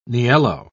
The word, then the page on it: niello: British English